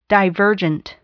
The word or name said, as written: divergent